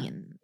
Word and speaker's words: Bellion: Bellion. – Is that how you say it? – Yeah. – Bellion.